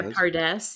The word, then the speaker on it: Pardes: Am I saying that correctly? The Pardes? Yeah, Pardes.